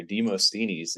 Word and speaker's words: Demosthenes: But yeah, for all the Greek listeners out there, Demosthenes is how you pronounce that if you got a little bit of Greek. And or we also had Nemesis, aka N3M3S1S.